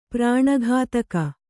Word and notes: ಪ್ರಾಣಘಾತಕ: prāṇa ghātaka

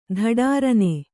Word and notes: ಧಡಾರನೆ: dhaḍārane

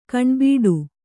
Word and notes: ಕಣ್ಬಿಡು: kannada

